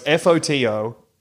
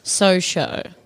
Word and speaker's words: Fotososho: It is spelt I.am photo.social. And so it's FOTOSOSHO. – Photo. So F-O, wait. So. – It's like so-so, but with an H after the second S. Yeah. – So-sho.